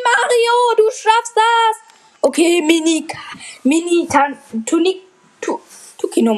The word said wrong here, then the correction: Tanuki: Tu Tanuki. Tukino. Tanuki. Tukino, sage ich immer. Tanuki Mario. Was ist das für ein Name, Tukino Mario? Tanuki Mario. Ach, Mann, ich hab's so lange falsch gesagt.